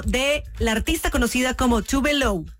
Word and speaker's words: Tove Lo: O sea, se llama Tove Lo, o sea, Tove Lo, es la pronunciación, pero su nombre real es Eva Tove Elsa Nilsson.